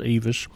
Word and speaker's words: IWIS: It's spelled like IWIS, but it's pronounced Evis.